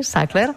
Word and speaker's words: Sackler: La història d'aquesta família, de la família Sackler, es pronuncia així, Sackler, gira al voltant del poder, dels diners.